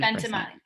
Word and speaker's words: Ventimiglia: First crush I would have to say is, I don't even know if I'm pronouncing his name right, but Milo or Milo Ventimiglia. – Oh, 100%. Ventimiglia from This Is Us.